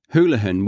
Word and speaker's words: Houlihan: Again, that who sound, Houlihan.